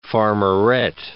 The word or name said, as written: farmerette